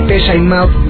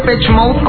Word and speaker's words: Depeche Mode: Y cabe aclarar, poniéndome algo purista, que esa es la pronunciación correcta de la agrupación de Pesha and Mouth, no de Pech Mouth como muchos creen, pero bueno, más allá del nombre, es momento de recordar uno de sus temas más importantes, Personal Jesus.